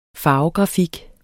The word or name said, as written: farvegrafik